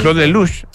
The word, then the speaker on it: Claude Lelouch: Claude Lelouch.